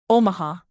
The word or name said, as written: omaha